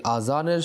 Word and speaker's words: আজান: এই আজান শব্দটি আমরা অনেক সময় উচ্চারণেই ভুল করে থাকি, কেউ আজান বলি, কেউ আজান বলি, তো এই আজানের সঠিক উচ্চারণ হলো আজান।